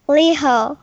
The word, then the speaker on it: Liho: I want to just check something because we were talking before about how to properly pronounce your name, and I said, oh, it must be Riho, and then Naho said, no, it's Liho, and then maybe in Japanese pronunciation it's Liho, but I'm not really sure.